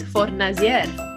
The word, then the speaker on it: Fornasia: Yes, you got the Australian one. I can give you the proper Italian Venetian. – I want to hear the OG version, please. – It is Fornasia.